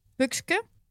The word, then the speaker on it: Puxque: Uh, my Twitch name is a little bit complicated for you, I think, then, but it's it's pronounced as Pukske.